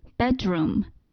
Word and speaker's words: bedroom: 这里呢，bed 它的尾音是的，和 room 它的起始音 r。放在一起呢，就可以读成 dr，所以 bedroom 就可以读成 bedroom bedroom。